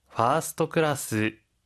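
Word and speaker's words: 파스트 클래스 (ファーストクラス): 파스트 클래스, 파스트 클래스. – 파스트 클래스, 퍼스트 클래스인데요. 선생님, 혹시 발음 팁 있을까요? – 한국어와 모음이 다른 점에 주의해 주시고요. 특히 퍼스트는 파스트라고 발음합니다.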